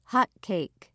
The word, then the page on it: hotcake: American English